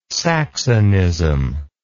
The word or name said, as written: saxonism